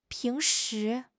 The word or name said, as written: ping2 shi2